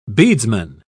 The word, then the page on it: beadsman: British English